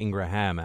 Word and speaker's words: Ingraham: It's a script that plays out time and again, and we just saw it happen now with Laura Ingram. I think Ingraham, actually. I need to make sure I say the name properly.